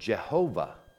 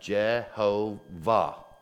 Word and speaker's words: Jehovah: In fact, you may find in some older Bibles, you may find the word Jehovah. Perhaps you have heard that. And so that is actually a German assigning vowel sounds, and instead of doing it like this, the Y has become kind of a hard sound, a J. And then instead of an A, you have an E here,